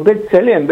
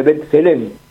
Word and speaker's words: B'Tselem: Un estudio que hizo un colectivo que se llama Colectivo Feminista Palestino, que está refrendado por B'Tselem.